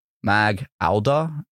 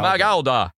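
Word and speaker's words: Mac Aodha: M-A-G space A-O-D-H-A. – A-O-D-H. Mag-Alda? – Mag-Alda. – Mag-Alda.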